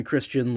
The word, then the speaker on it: Christian: Mine is I'm Christian like the religion, Angelus like Los Angeles, and yet everyone still gets my name wrong, which is very bizarre.